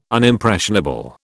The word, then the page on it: Unimpressionable: British English